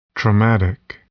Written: traumatic

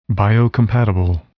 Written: biocompatible

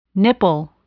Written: nipple

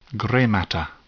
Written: gray matter

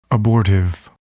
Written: abortive

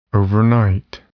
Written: overnight

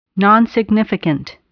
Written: nonsignificant

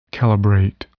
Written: calibrate

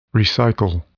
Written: recycle

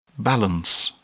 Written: balance

